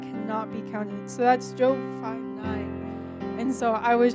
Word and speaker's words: Job: Scriptures that kind of just touched my heart right now, but and I don't know, do you pronounce it as Job? Is it Job? Yeah, okay, that's what I thought.